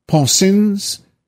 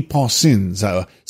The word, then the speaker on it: Poncins: de Pensin, it's spelled P-O-N-C-I-N-S. Pensins, so it's L. de Pensins, something like that.